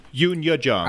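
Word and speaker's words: Youn Yuh-jung: Yoon Jin Young, is that how you pronounce her name? – Yoon Ya Jung.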